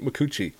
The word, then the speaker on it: Micucci: Oh, and yes, I'm pronouncing her name right, Kate Micucci.